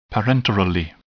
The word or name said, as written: parenterally